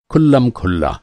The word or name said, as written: खुल्लम